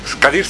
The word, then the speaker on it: Kadir: Kadir es Kadir. O sea, es curioso, muchos idiomas, como el español, se acentúan en la sílaba para que sea una palabra grave. – Uh-huh. Entonces aquí es Kadir.